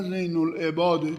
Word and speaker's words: زین العباد: زین العابدین، این در تلفظ ما و ادبیات ماست که زین العابدین میگیم بهش، وگرنه زین العباده، تو احادیث اومده که او زین العباده.